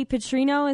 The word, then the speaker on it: Petrino: I wanted to talk a little bit about what's going on in Arkansas right now with Coach Bobby Petrino. Is that the correct way to pronounce it? – That is it, yep.